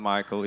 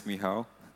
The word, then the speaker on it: Michał: Hi, I pronounced my room name wrong. It's not Michael, it's Michał.